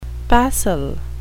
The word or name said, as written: passel